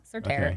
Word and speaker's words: Surterre: But it's S-U-R. Sir, and then T-E-R-R-E, so Surterre.